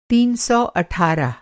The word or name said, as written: तीन सौ अठारह